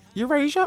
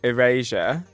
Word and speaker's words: Erasure: Eurasia or is it Erasure? – Erasure. Are you, the band? – The band. – Yeah, Erasure.